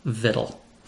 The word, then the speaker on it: victual: If you pronounced it victual, you're in good company. At the very least, you're in the same company with Tessa and me, and I like to think we're pretty good company. but according to every dictionary we checked, including Merriam Webster, American Heritage, and even the venerable Oxford English Dictionary, the word is properly pronounced vittle.